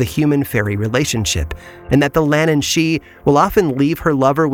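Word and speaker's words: Lhiannan Shee: It's spelled differently but pronounced the same, Lannan she, although this creature is far more dangerous than her Irish counterpart.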